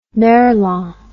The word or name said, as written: merlan